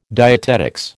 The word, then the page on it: dietetics: American English